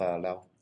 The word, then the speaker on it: Lao: Yeah, he's half Lao. – How is it pronounced? Is it Lao? Is it Laos? I don't know. – Lao. – I'd say. – I think Lao.